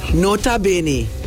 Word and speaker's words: nota bene: Pronounce it. – Nota bene. – Okay. – So N-O-T-A for the N in note. – Right. – And like Benny Ball, B-E-N-E. – Okay. – Yeah. So Nota Bene really literally translates to note.